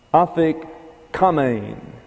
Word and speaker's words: afikoman: And so the way you pronounce this is afikamein, afikamein.